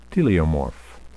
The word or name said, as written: teleomorph